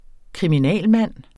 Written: kriminalmand

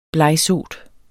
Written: blegsot

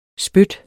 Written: SPYT

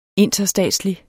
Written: interstatslig

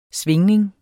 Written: svingning

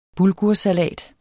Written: bulgursalats

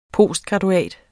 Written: postgraduat

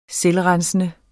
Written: selvrensende

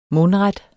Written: MUNDRET

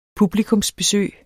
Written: publikummer

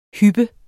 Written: hyp

